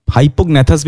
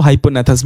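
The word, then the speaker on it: hypognathous: Hypognathus bhi bol sakto, hyponathus bhi bol sakto, because G is silent here.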